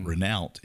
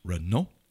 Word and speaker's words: Renault: It's not Renault. It's Renault.